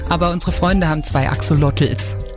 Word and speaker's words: Axolotls: Wir hatten heute schon von Alpakas äh, gehört, von Ratten und Spinnen und äh, jetzt gibt's also auch noch Axolotls. Habe ich es richtig ausgesprochen? ja, Axolotls, das sind äh, mexikanische Lurche.